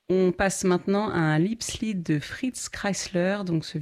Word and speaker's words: Liebeslied: Et ce Liebeslied, Liebeslied, ça veut dire chagrin d'amour. – Ah, j'avais mal prononcé. Alors, Liebeslied.